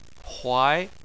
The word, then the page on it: huai: 2